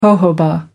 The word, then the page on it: jojoba: American English